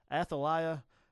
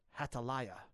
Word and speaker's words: Athaliah: Now, the Hebrew pronunciation I looked that up is Hataliah. That's how they say it. They say that's a Hebrew interpretation, but the way we say it in English is the Athaliah.